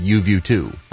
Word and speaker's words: YUVUTU: I think what's it's YUVUTU. – Okay. – It's spelt that way, which is kind of funky, but I believe they call it UView 2.